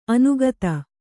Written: ಅನುಗತಿ

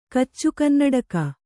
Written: ಕಚ್ಚುಕನ್ನಡಕ

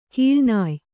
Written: ಕೀಳ್ನಾಯ್

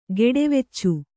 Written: ಗೆಡೆವೆಚ್ಚು

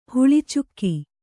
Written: ಹಳಚಿಗೆ

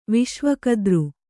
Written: ವಿಶ್ವಕದ್ರು

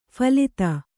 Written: ಫಲಿತ

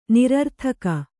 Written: ನಿರರ್ಥಕ

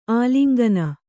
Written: ಆಲಿಂಗನ

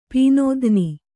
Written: ಪೀನೋಧ್ನಿ

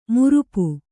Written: ಮಾರಾಪು

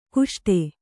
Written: ಕುಷ್ಟೆ

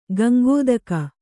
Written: ಗಂಗೋದಕ

